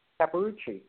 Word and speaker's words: Capparucci: Capparucci? You've been saying it right. Capparucci. Yep. You've been great.